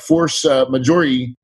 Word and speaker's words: force majeure: And we're just going to use the force majeure, probably saying that wrong. – No, you're right. – Right? – Got to say with the French accent.